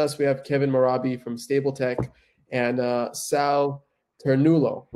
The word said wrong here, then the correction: Ternello: right? Am I pronouncing that right? Ternulo? – Ternello, but close, yeah, yeah. Okay, Sal Ternello from KPMG.